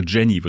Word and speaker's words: Jene: Also ihr Mädchenname war Jene, J-E-N-E, französisch. Und die wussten immer, das kommt aus Frankreich, aber die wurden wohl früher mit Nachnamen eher so Jenny, wird das ausgesprochen.